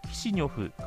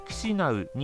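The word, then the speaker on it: Chișinău: モルドバの首都の呼称をロシア語による読み方に基づくキシニョフからモルドバで公的に使用されているルーマニア語による読み方に基づくキシナウに変更すると発表しました。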